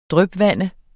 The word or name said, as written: drypvande